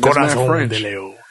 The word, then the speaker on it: Corazón de León: Even though the way you say it properly is Corazón de León. Nah, Corazón de León. – Corazón de León.